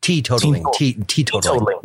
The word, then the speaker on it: teetotaling: Now, as a teen-totaling dude, oh, did I say that right? Teetotaling. – Yeah, yeah, he's a teetotaler, which means he doesn't drink anymore.